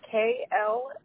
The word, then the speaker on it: Kloe: Okay, and then Chloe, am I spelling this correctly? Because there's different ways of spelling it. Is it Chloe? – My first name is spelled Kloe.